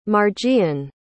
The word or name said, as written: Marjean